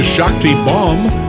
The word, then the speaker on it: Shakti Baum: First timer alert, this is Shakti Baum who is the owner and executive chef at Etta's Kitchen.